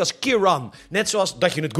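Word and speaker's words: Ciarán: En toch even over storm Kiran dat spreek je dus uit als Kiran, terwijl je het dus anders schrijft. Het is namelijk een Ierse naam.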